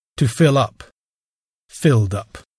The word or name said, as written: fill up